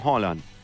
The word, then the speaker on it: Haaland: No. Ellos no utilizan, no es como el español, que hay vocales cerradas o abiertas. Ojo, eso es muy distinto. O sea, la A. No, ellos, Erling Haaland, como entre O y A.